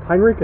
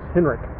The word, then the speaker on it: Heinrich: And I would also like to point out that in our episode on vampire literature, I mispronounced Heinrich as Henrik.